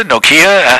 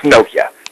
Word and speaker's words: Nokia: Is it Nokia? Nokia? How exactly is the name pronounced according to the Finns who made it up? – It's Nokia. – Nokia. – Emphasis is always on the first syllable.